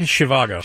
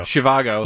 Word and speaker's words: Zhivago: They see it as more something like, you know, Zhivago, or how do you say it? – Zhivago. – Zhivago.